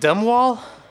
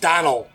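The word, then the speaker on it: Domhnall: Isn't his name like Dumbwall? – It's spelled weird, but it's pronounced Donal. It's spelled Dom H. Null. – Ugh. – The M and the H are silent.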